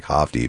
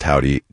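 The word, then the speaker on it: Hovvdy: They spell Howdy with two V's, of course, two V's instead of the W, so it kind of looks like Hofty, but pronounced Howdy.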